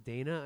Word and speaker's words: Danya: Dana, spelled D-A-N-Y-A. So it's spelled like Dania? – Dania, but it's pronounced Dana, I think. – What? – Yeah. – That's even dumber than, D-A-Y-N-A.